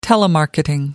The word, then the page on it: telemarketing: American English